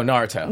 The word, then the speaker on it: Naruto: Naruto? – Oh, Naruto.